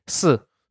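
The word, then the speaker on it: Si (四): How do you say it? Teach me. – Si. Which also sounds like the word for death.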